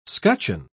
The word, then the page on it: scutcheon: British English